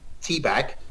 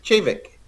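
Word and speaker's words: Te Bheag: There's a distillery called pronounced T, spelt T-E, and then it's a new word, B-H-E-A-G. So you'd think teabag or tibag. But it's actually pronounced Chavig.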